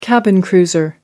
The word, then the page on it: cabin cruiser: American English